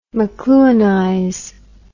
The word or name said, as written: mcluhanize